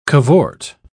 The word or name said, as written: cavort